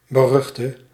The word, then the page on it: notorious: American English; [noʊ.ˈtɔɹ.i.əs]